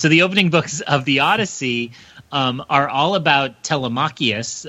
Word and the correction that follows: Telemachus: I added a syllable there. Telemachus, Odysseus's son, travels around hearing all the stories of the other heroes of the Trojan War who came home.